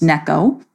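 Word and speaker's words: Neko (ねこ): that's pronounced Neko.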